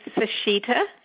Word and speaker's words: Sesheta: Dr. Brenda, you know it's Sesheta.